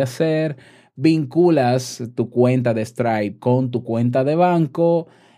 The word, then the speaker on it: Stripe: Se escribe e S, se escribe S-T-R-I latina P-E, Stripe. Así se escribe, bueno, así se pronuncia en español.